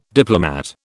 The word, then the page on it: diplomat: British English